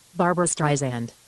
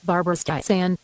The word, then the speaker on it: Streisand: Okay, Barbara Streisand. – Okay, the last syllable should be pronounced with an S, not a Z. – Barbara Streisand.